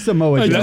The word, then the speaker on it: Samoa Joe: At the end, he says, Senor Joe. – Senior Joe? – It's fucking, it's Samoa Joe.